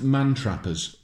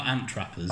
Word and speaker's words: Mantrappers: I'm pretty sure the second part's pronounced Mantrappers. – I think you're right there. – Yeah. – Not Ant Trappers.